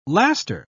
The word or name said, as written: laster